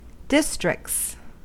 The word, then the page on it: districts: American English